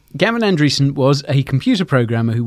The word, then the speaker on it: Andreessen: Andreessen. Yeah. When you look at his name, it looks like Anderson spelled with a typo in the middle. But yeah, Gavin Andreessen.